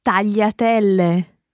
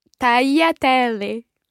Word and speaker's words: tagliatelle: And then another type of pasta that is very commonly mispronounced, starting with the letter T. – I think you mean here tagliatelle. – Listen again.